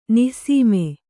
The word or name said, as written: ನಿಸ್ಸೀಮ